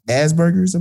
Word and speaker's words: Asperger's: Like, it almost to me seems unbelievable that he's this far advanced a human being, and he has Asperger's, I believe you call it.